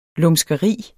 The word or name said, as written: lumskeri